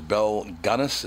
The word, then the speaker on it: Gunness: In the pantheon of serial killers, Belle Gunness, is that how you say her name? – Yes.